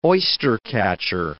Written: oystercatcher

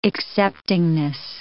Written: acceptingness

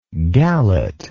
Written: gallet

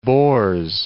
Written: Bors

